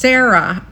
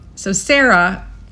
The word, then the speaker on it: Sara: I mean, my name in its regionalism would be pronounced Sarah. because, you know, I'm from Chicagoland. – You could pull it out. – Yeah. So Sarah is how my family would call me.